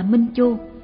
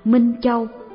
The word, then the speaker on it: minh châu: Mỗi người mỗi có minh chu trong nhà, bản này đọc là minh chu, bản in năm 1932 đọc là minh châu. Chữ chu này đọc là châu thì đúng hơn, mỗi người ai cũng có hạt minh châu trong nhà.